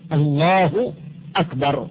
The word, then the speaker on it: الله أكبر: إذن النطق الصحيح الله أكبر مبتدأ وخبر وبالحذف. اللحن الثاني الذي هو مثل هذا، من يمد الباء في أكبر، الله أكبر، مد الباء ألف بعد الباء يمد هكذا يبطل المعنى، لأن أكبر لحن يخل بالمعنى، وهو اسم من أسماء القبول، فلا بد من البيان والتنبيه على الناس حتى يجتنبوا هذا اللحن الفاحش.